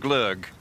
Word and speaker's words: Gløgg: Gløgg? – Yes, Gløgg.